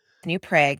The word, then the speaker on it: New Prague: New Prague, because we're in Minnesota. My father was very much a New Prague, because that's how you say it in Czechoslovakia.